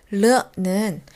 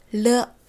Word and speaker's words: 了: 르는 동사의 완료를 나타낼 때 사용하는 몇 개 안 되는 중국어의 과거형 표현이에요. 르, 르.